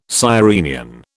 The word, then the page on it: sirenian: British English